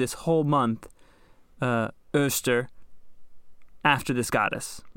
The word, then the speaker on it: Eostre: These people are all pagan, and they have a goddess named Oster. – Spell that for me. – So, E-O-S-T-R-E. – Oster. Slower? – E-O-S. Us. – Okay. – T-R-E. Ter.